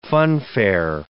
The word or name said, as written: funfair